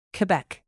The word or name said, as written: quebec